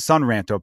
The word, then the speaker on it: Sun Ranto: And he's also part of a great Cubs podcast that I definitely encourage everyone to check out called the San Ranto podcast, or the Sun Ranto podcast, I should say. I've talked about this with him. I always want to say San Ranto because San rhymes with Ron, like our guy Ron Santo.